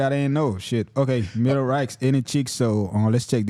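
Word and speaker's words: Mellow Rack: Comment prononcer nom amen. – On fit Melo, Melorax. – Oh, Melorax, OK, yeah.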